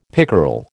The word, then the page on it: pickerel: American English